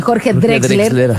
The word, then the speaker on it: Jorge Drexler: Nunca sé decir bien, Jorge Drexler, ya lo dije bien.